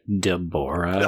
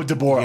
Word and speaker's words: Deborah: Also, it's Deborah. – Oh, Deborah. Yeah. Oh, my God. – Just saying. – Like, the fucking... It's like the joke, you know, you put the wrong emphasis on the wrong syllable.